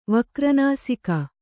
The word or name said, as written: ವಕ್ರನಾಸಿಕ